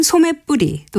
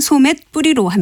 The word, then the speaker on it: 소맷부리: 이 경우에는 소매의 사이시옷을 써서 소맷부리라고 쓰고 발음은 소맷뿌리 또는 소맷뿌리로 합니다.